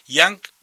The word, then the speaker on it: Young: Yang, como se escribe la palabra joven en inglés, pero la gente de la ciudad de Young llama así a su radio y denomina así a su ciudad, Young, un pueblo muy lindo del departamento de Río Negro, en, por supuesto, en el hermano país, en Uruguay.